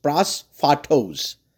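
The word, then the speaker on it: prosphatos (πρόσφατος): And the way you pronounce it, and I'm going to try it, is prosphatos.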